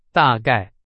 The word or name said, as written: 大概